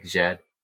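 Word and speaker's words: Djed: It's spelled D-J-E-D. I swear, this is a trick question. You probably don't pronounce the first D. – Yeah, it's probably just like Jed. – Jed.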